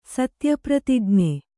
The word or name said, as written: ಸತ್ಯಪ್ರತಿಜ್ಞ